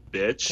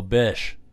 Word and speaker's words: Bich: It's called a BC Rich Bitch. – Can I wear protection? – But it's spelled B-I-C-H. – Oh, Bish. – Yeah. BC Rich Bish, I guess. I associate Bish with B-I-S-H.